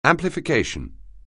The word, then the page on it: amplification: anglais